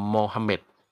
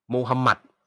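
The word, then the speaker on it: Mohamed: รับที่ประสบความสำเร็จในระดับสูงนะครับในวงการฟุตบอลนะครับนั่นก็คือโมฮัมเหม็ดมูฮัมหมัดนะครับแล้วแต่จะออกเสียงสไตล์ไหน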